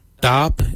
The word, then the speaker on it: Dhaab: Today we will look at, for knife we say dab. Darb is spelled D-H-A-A-B.